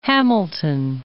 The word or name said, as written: Hamilton